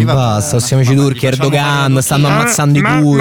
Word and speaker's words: Erdogan: Ecco, innanzitutto si pronuncia Erdogan, che la G è muta, primo.